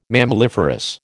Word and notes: mammaliferous: American English

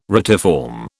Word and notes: retiform: British English

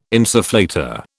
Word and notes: insufflator: British English

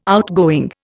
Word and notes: outgoing: Indian

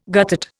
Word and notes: guttate: Indian

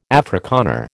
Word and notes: Afrikaner: American English

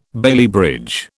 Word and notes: Bailey bridge: British English

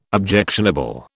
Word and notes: objectionable: British English

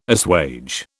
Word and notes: assuage: British English

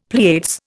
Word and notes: Pleiades: Indian

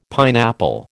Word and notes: pineapple: American English

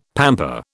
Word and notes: pamper: British English

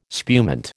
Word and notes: spumante: American English

